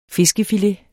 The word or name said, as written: fiskefilet